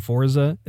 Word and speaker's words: Forza: of the word Forza. I'm used to saying Forza. It's actually Italian. It's Forza. – Yeah, it's like almost like a silent T.